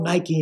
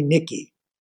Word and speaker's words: Nike: When we were leaving, Michael told me outright he called Nike Nikki. He didn't know how to pronounce Nike.